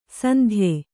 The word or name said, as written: ಸಂದೆಯ